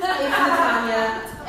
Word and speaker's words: metanoia: who taught me that the word is actually metanoia. So, fun fact, we've all been saying it wrong, it's metanoia, and now we know.